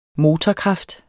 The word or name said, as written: motorkraft